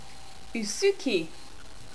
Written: usuki

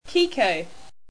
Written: kiko

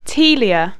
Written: taelia

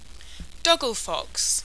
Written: doglefox

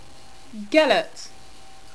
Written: gelert

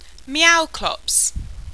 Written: Meowclops

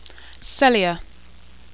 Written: Psellia

